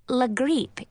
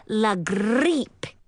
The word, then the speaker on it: la grippe: It is pronounced la grippe, but sounds even nastier when someone like Grand-Mère or Madame Hélène says it, because they both roll their R's and pronounce the letter I like E-E, so it comes out sounding like la grippe.